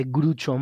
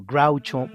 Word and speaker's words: Groucho: De hecho, en inglés no se dice Grucho Marx, sino Groucho Marx.